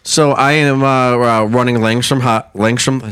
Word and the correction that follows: Langstroth: Is that how you spell it? – Langstroth. – Langstroth. Yeah. Langstroth hives.